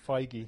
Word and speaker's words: Feige: F-E-I-G-E. Feige. – He's made fake, up as he goes along. – No, it's Feige. That's how he pronounced it.